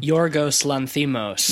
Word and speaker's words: Lanthimos: Yorgos Lanthimos.